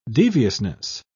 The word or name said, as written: deviousness